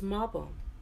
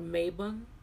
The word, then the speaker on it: Mabon: So, the Sabbath for September is Mabon, or Mabon, or Mabon, however you want to pronounce it, M-A-B-O-N, however you want to pronounce it.